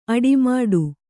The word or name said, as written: ಅಡಿಮುಡಿ